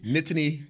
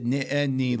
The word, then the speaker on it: Nittany: Yeah, you gotta throw the T's in. – Yeah, a nitany. – A lot of people just roll through it, and it's almost like 2 syllables, but it's Nittany Lions.